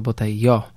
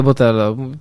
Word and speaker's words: Botello: że tak naprawdę wymawia się go nazwisko Botello. – I bo teraz Meksykaninem, tak, z pochodzenia. – Dokładnie, więc, ale ja mówię Botello, trudno, tak, masz napisane w Polsce, czytamy po polsku.